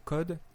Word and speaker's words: Kod: Donc, le premier, c'est un éditeur qui s'appelle Code, K-O-D donc euh, voilà, ça, s'entend comme un code CODE, mais ça s'écrit KOD.